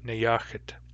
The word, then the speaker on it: Neyooxet: His native name is Nyakit, which is spelled Neyooxet, Nyakit.